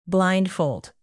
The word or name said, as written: blindfold